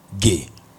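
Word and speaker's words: ge (γη): Ge. In the Greek it's pronounced ge. G-E.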